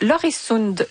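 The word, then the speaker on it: Øresund: Je suis mélangée avec le pont de Loresund. J'ai choisi la prononciation danoise, mais j'aurais pu aller du côté de l'accent suédois, Yann.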